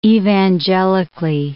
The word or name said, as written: evangelically